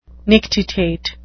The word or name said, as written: nictitate